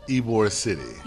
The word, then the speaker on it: Ybor City: Ybor City.